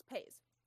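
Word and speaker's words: Paizs: It's not Pies, it's Pays.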